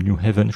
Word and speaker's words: New Haven: donc on est à Yale, c'est dans la ville de New, New Haven, je prononce bien.